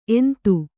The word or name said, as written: ಎಂತ